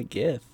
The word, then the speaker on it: Gith: The Gith. – Yeah. – Is it pronounced Jif or Gith? – It's it's Gith, actually.